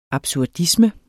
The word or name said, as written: absurdisme